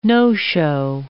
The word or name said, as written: no-show